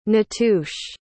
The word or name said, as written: natuche